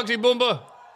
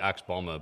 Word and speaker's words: Axe Bomber: Oh, Oxy Boomba! That's Hogan's pronunciation of it, by the way. It's supposed to be Axe Bomber, but he just, one time, tonight in the Egg Dome, Axe Bomba!